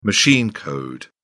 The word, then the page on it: machine code: British English